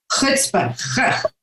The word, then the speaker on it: chutzpah: Thank you for taking on having the chutzpah. I've heard that a lot of different ways, but the way you said it is different. How do you pronounce it again? – Chutzpah.